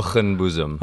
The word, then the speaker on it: Hoogenboezem: Hoogenboezem.